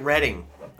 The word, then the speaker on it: Reading: Yeah, you pronounce it Reading.